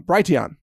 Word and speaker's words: Brighteon: Brighteon is how it's pronounced.